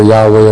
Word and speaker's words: Yahweh: We have a tetragrammaton there, J-H-V-H or Y-H-W-H, whichever you Hebrew pronunciation you prefer, Yahweh or Jehovah then.